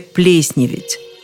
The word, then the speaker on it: плесневеть: Я о слове «плесневеть». Плесневеть и заплесневеть. Да, именно такое ударение рекомендуют нам все словари.